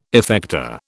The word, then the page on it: effector: British English